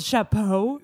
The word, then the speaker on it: chapeau: I think one thing that's interesting about the old hat, about the old chapeau. Ooh, did I say that right? – You did.